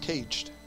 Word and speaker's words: sagad: Another one is sagad, which is pronounced caged.